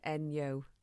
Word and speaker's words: Enjo: And it's actually spelt E-N-J-O, isn't it? – That's correct, yes. – Yeah, but pronounced Enyo.